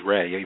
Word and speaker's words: Rei: My hero's name is Ray, even though it's spelled R-E-I, and my heroine's name is Rome.